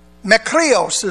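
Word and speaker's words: makrios (μακάριος): Makrios is the way it's pronounced.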